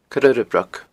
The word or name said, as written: Coire Urra Broc